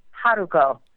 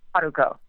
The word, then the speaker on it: Haruko: Yeah, there's a great blooper reel of me trying to pronounce that on the DVD set, but I think it's Haruharu Haruko. And I'm sure that there are anime fans everywhere. No, you did not pronounce it on the right syllable. But I think that's her name. – Very good.